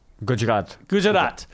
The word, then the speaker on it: Gujarat: Gujarat.